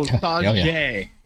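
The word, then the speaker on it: Sauget: So Saget is how it's said.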